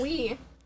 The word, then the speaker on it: oui: I said oi because there's an oi, a yogurt that's called oi. – Oh, I've been saying it wrong. – Actually, no, you pronounce it wee. – Wee? – Wee. French. O-U-I.